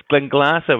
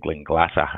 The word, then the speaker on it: Glenglassaugh: It's Glenglassaugh, which normally catches people out. There are far more letters in there than you actually need. So I always chop off really the last three letters, stopping at the A, which is just pronounced then Glenglassaugh, a soft A at the back.